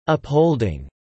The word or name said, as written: upholding